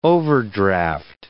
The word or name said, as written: overdraft